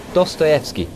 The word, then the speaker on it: Dostoevsky: Dostoevsky, sir.